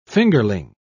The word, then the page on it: fingerling: British English